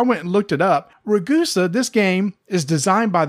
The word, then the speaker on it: Ragusa: And it's spelled RAGUSA. Immediately I thought, RAGUSA. No, it's Ragusa. – Ragusa. – It's a place in Italy.